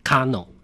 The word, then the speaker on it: KANO: 今天呢，我们要来介绍算是一个热血棒球的电影《 Kano》。 – 是这个 Kano 呢，主要的这个故事的这个发生的这个背景呢，是在日本统治台湾时期的 1931年啊。当时呢，在这个嘉义农林啊棒球队啊，它其实就是加农了啊，用日文啊来发音就叫做 Kano 啊。